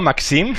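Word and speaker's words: Màxim: Carlas Mesa, nuestro compañero y amigo, y, Carlas Mesa de Radio Nacional, me está escribiendo y dice: pronuncia bien Maxim, que siempre lo dices mal. Lo he dicho bien, ¿no? Maxim. Sí, Maxim.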